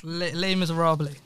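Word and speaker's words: Les Misérables: With the singing and all of that, it was just like, I watched Les Miserables. Whoa. How do you say that? – Les Miserables.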